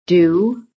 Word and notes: DO: American English

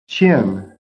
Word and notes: CHIN: American English

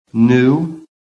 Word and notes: NEW: American English

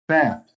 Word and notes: BAT: American English

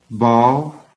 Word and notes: BALL: American English

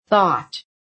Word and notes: thought: American English

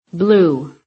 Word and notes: BLUE: American English

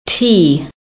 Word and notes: tea: American English

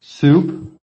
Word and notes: soup: American English

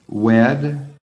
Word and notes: WED: American English

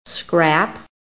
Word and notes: scrap: American English

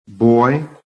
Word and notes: BOY: American English

